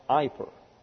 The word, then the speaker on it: eiper: E-I-P-E-R, pronounced iper.